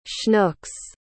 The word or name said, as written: schnooks